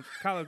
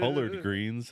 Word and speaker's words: collard: Collard greens. They're not collard greens. Collard greens. – I ain't calling them colored greens. – They're not called colored greens. – It's collard. Collard greens.